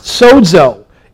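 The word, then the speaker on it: sozo (σῴζω): The word healed in our English Bibles comes from a Greek word pronounced sozo.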